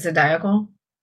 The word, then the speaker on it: zodiacal: How do you say it? Zodiacal? – Yes, zodiacal. – Because I would have never figured that one out.